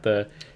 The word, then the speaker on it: ye: in like old English they had the ye, like ye old tavern. It's not actually pronounced ye, it's actually just the.